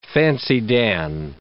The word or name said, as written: fancy-dan